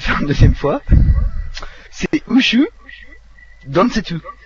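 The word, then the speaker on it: Ushu Densetsu (宇宙伝説): Je vais vous le prononcer. C'est Ushu Densetsu.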